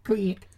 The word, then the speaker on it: Crick: Crick. – Like how you pronounce Creek in Idaho? – Crick, yeah.